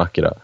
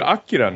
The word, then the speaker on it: Akira: Kanadas motorcykel från Akira. – Mm. – Och så Modo hade robotarm och... – Ser du Akira nu, eller? Det är så det uttalas. – Akira.